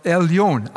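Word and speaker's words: Elyon: Die Betonung ist auf Elion, also Gott, der höchste Gott.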